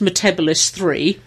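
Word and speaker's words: Metebelis: It is actually pronounced Metabolis 3. It's just John Pertwee was getting it wrong.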